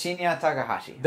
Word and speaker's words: Shinya Takahashi: It's not offensive. That's how they say it. – Okay. – It's Shinya Takahashi.